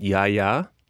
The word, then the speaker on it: Gaia: I've got another red, which is it's pronounced Yaya, which is G-A-I-A, Yaya Arahiriko.